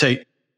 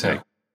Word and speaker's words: Haidt: I heard an interview the other day with Greg Lukianoff, and he pronounced Jonathan's last name. So I was like, oh, okay. – Oh, go for it. What did he say? – It's Haidt. – Oh, okay. It is Haidt.